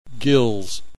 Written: gills

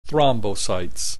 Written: thrombocytes

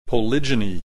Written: polygyny

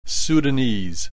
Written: Sudanese

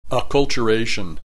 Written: acculturation

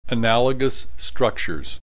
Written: analogous structures

